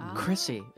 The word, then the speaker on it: Crissy: Did I pronounce it with an H or without an H? – Well, you pronounce it with an H, but it has no H. – There's no H in it. – What do you mean he pronounced it with an H? – Silent C. – He pronounced it C-H-R-I-S-S-Y. – I pronounced it Chrissy. – It's Chrissy. – And it's pronounced C-R-I-S-S-Y. – Right. Say both. – So instead of Chrissy, you say Chrissy.